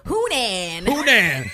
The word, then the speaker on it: Hunan: So Metro UK reports that 44-year-old man Tan Nan of Hunan, am I saying this right? – Hunan was drinking heavily with a group of his friends.